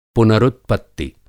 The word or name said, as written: पुनरुत्पत्ति